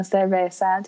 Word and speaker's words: cerveza: licky, and a lot of uh, cerveza. Did I say that right? – Yes.